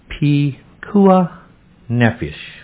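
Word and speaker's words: Pikuach Nefesh: And that's pronounced Pekuah Nefesh.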